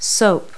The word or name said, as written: soap